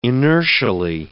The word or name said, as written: inertially